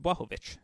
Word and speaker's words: Błachowicz: And people ask me, why do I pronounce it Błachowicz? Well, because that's how Jan told me it's pronounced.